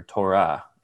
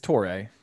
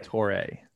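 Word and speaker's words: Touré: Is there anything, or Torah, is that how you say it? – It's Torre. I found a video of him on YouTube pronouncing his name, and you know, I'm not the best, but I want to learn it. So I'm Samori Torre.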